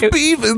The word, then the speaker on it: Bevins: Beavins? It's Beavins! – It was supposed to kind of rhyme with Beavis, and Beavis is stupid.